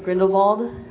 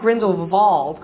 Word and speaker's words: Grindelwald: I was wondering what you guys thought about Grindelwald. – Mm-hmm. – And notice that the W is pronounced V as in German pronunciation.